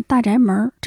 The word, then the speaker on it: 大宅门: 电视剧《大宅门》导演郭宝昌十月十一日在北京离世，享年八十三岁。尊重逝者及家属意愿，丧事从简，不举行遗体告别仪式。我应该是去年才完整看了一遍《大宅门》。嗯，考虑到它是一个北京的作品，发音应该是大宅门，真的是精彩绝伦的群像戏，尤其女性角色写得非常的鲜活。